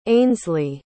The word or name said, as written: ainsliegh